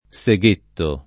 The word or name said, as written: seghettare